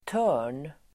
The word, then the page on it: törn: [tö:r_n]